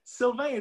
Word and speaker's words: Sylvain: And Sylvain is a little bit hard to pronounce in English. Often. And I'm glad because you did not call me Sylviane, because a lot of people around the world call me Sylviane. They just reverse the A and the I and just like Sylviane.